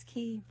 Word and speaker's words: Quay: How are you going to do Q-U-A-Y? Is it Key? – It's Key.